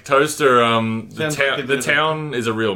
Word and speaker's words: Towcester: Because I went to a public school first in a town called Toaster, which was really great. It's spelled Towcester, like you know, like Leicester.